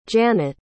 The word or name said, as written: Janet